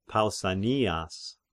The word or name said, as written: Pausanias